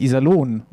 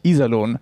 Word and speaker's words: Iserlohn: Sagst du, sagst du Iserlohn und mit der Betonung auf I, dann rasten die schon aus im Internet. Weil es heißt nämlich Iserlohn und nicht Iserlohn.